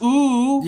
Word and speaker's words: Oooh!: Even the name of the song is just O-O-O-H with an exclamation point. – Yeah, I think it's pronounced ooh.